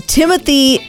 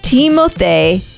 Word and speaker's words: Timothée: And then Timothy Chalamet. That's how I would say it. It is not correct. It's Timothée.